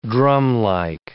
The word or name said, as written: drumlike